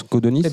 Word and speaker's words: Codenys: Comment on prononce Codonis Codonis.